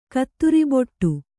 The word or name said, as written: ಕತ್ತುರಿಬೊಟ್ಟು